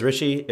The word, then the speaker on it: Rishi: Hey, my name is Rishi. It rhymes with squishy.